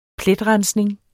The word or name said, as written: pletrensning